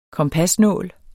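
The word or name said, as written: KOMPASNÅL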